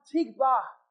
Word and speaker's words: tikvah: In fact, the word hope in the Hebrew is a word pronounced tikvah.